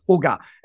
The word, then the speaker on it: jugar: So Sugar Life, I think it's actually pronounced Huga. It's Spanish for play, but I like saying Sugar Life better, and that sort of rolls off the tongue.